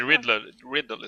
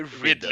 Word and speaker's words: Ridley: Du kan ju men prata om Daisy Ridley istället. – Daisy Ridley, inte Riddle. Ridley.